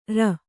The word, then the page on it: ಱ: ṛa